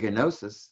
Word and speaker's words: Gnosis: I don't know if I got it right, but it looks to me like it's probably a silent G, but we can call it Gnosis.